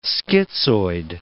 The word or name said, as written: schizoid